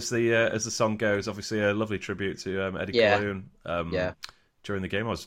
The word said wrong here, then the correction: Coughlin: Eddie Coughlin. I've heard he's pronounced actually.